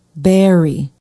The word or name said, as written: barre